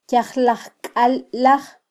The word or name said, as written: kyajlajkallaj